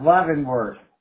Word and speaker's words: Leavenworth: Yeah, Leavenworth. – Leavenworth. Okay. – Yeah. And that's in Kansas. So you'll have to look that up. I think Leaven is spelled the same as heaven except with an L.